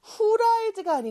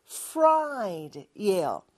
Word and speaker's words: fried: 후라이드가 아니고 fried이에요. 근데 F하고 R 둘 다 우리말에는 없는 발음이라 조금 어려울 수 있지만 저와 함께 연습을 해볼게요.